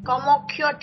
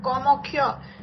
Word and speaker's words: Kamakhya: Kamakhya temple. Kamakshi Amman, this is South India. But Kamakhya is the Bengali Assam.